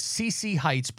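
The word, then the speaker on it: Sziszi Hites: Her name is CC Heights, but it's spelled S-Z-I-S-Z-I. One word together like that. CC Heights. And Heights is H-I-T-E-S.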